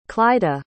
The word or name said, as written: clyda